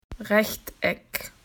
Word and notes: rectangle: Australian English

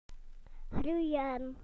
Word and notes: to grow: British English

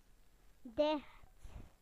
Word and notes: closed: British English

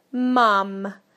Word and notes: mum: British English

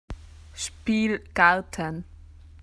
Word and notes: playground: Australian English

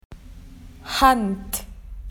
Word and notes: hand: Australian English

